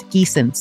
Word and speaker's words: Giesen: It's Giessen, spelled G-I-E-S-E-N. Sounds like the geese that maybe you see at the park.